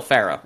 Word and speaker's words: Pharaoh: Oh, Pharaoh like that. I was thinking F-A-R-O for whatever reason. It is written that way, but phonetically it is still Pharaoh.